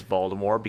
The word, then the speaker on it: Voldemort: No, yeah, Voldemort is the correct way to say it now.